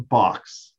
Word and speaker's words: Box: It's spelled as if it's Philbo Park, but Phil pronounced his last name Box.